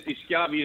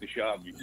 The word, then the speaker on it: DiSchiavi: It is DiSchiavi in Italy, but in America we pronounce it DiSchiavi.